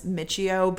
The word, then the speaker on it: Michio: I need a guy with a simple last name because mine is Michio, but like people are like Miko.